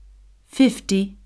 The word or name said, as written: fifty